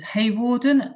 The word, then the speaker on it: Hawarden: Clementina, I think her first name is Hawarden.